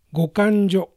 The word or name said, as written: Gokanjo